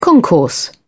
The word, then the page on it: concourse: British English